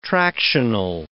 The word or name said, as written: tractional